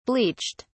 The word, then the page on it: bleached: British English